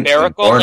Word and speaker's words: Barbaracle: Barbaracle, like Barbarian, yeah.